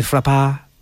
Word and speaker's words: Frappart: Stephanie Frappard. It's spelled F-R-A-P-P-A-R-T. Would that be French for frappa? – Frappa. – It's hardly frappart anyway, is it?